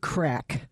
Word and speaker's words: craic: And it's, as we like to say, it's good crack. And that's spelled C-R-A-I-C.